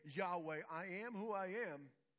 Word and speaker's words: Yahweh: It is such a beautiful name because in Hebrew, you have to inhale and then exhale to say the name correctly. Yahweh.